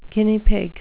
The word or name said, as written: guinea pig